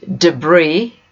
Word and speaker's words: debris: Debris is a noun, it's a French word, and that's why the final S is silent.